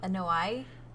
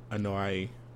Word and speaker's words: Anoa'i: Anoa'i? – Yeah. Anoa'i wrestling family,